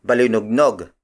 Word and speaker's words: balinugnog: The next word is balinugnog. Balinugnog. Balinugnog. Balinugnog is spelled as B-A-L-I-N-U-G-N-O-G.